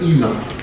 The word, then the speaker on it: Enon: There's a place known as Enon, or some pronounce it Enon. However you want to say it, it's the same spelling, same understanding.